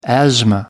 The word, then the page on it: asthma: American English